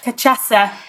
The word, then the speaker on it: Cachaça: Caipirinha, which is, I'm going to mispronounce it, cacha. Do you know how to say it? – Cachaça. Cachaça, the Brazilian.